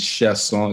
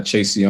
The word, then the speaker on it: Chasson: My name is Manuel Chasson. I do have to say something about my last name. It's Chasson. It's not Chasion. I understand it's spelled a certain way.